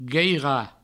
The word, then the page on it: gueirar: gueira; Provençal